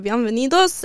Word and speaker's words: bienvenidos: Okay, bienvenidos. – Bienvenidos. – Did I get it wrong again? – You're pronouncing it French. – Really? Yeah, wait. – It's bienvenidos. – Oh, bien. Oh, I say bien.